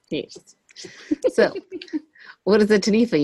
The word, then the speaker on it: Taniwha: And speaking of those Wikipedia lists, that's where I got this week's topic, the Taniwha, which is really fucking me up because it's spelled with a W and not an F, but there's an F sound.